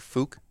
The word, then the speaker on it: Fuk: If I was in Japan, I would probably say Fuk.